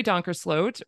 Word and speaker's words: Donkersloat: And I traveled with Mary Donkersloat, rhymes with oat.